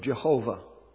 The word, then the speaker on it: Jehovah: Whenever they read the scriptures, if they came to the name of Jehovah, that's how we pronounce it in English, though it actually J used to sound like a Y, so it's Jehovah or something similar to that.